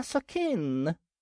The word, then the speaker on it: Сокин: «О, месье Сокин!» С ударением на последнюю букву произнесла высокая брюнетка, эдакая типичная обитательница вычурных социальных сетей с фотографиями.